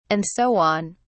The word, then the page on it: and so on: British English